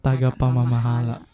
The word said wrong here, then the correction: Tagapamahala: Tongue twister. – Tagapamahala. Oh, really cool.